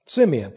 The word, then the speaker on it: Simeon: I don't know what Simon was doing. Am I saying it right? Was it Simeon? Simeon. What Simeon was doing at that particular moment, maybe he was washing his clothes.